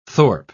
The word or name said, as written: thorpe